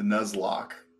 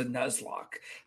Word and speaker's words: Nuzlocke: Nuzlocke? – Yes, the Nuzlocke. – The Nuzlocke. It's very close to Headlock, but it's a Nuzlocke.